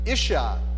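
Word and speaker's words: Isha (אישה): In the Hebrews, it's pronounced Isha. And it's derived from the Hebrew word for man, which is Ish. So Ish is man, Isha is woman.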